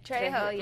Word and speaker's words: Trejo: So today we're going to highlight Danny Trejo. Is that how to pronounce it? – Trejo, yeah. Mm-hmm.